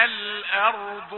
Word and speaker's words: الأرض: إذا السماء انشقت وأذنت لربها وحقت وإذا الأرض مدت. الأرض لا تقل الأرض، لا تقل الأرض، لا تفخم الهمزة مع الراء لمجاورتها، وهذا الخطأ نسمعه كثيرا ويمر علينا مر الكرام، لكن انتبه أنك تتعلم يعني دقة النطق بحروف القرآن العظيم.